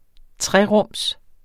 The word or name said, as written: trerums